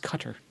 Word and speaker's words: Qatar: It's pronounced Qatar.